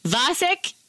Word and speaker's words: Vasek: Vasek.